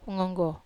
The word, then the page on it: ngonggo: Wamesa